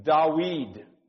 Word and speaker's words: Dawid: Dawid is the way you pronounce his name in Hebrew.